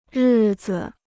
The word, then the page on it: 日子: rì zi